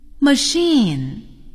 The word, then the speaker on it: machine: Machine, a washing machine. Chef, he's a chef. Chicago, he lives in Chicago. 今天挺有意思，一个字母组合 CH 有三种发音，它们是 CH、 K 和 SH。